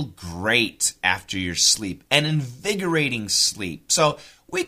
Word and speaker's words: invigorating: Today's expression is nice, listen carefully, an invigorating sleep. Invigorating.